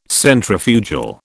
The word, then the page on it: Centrifugal: British English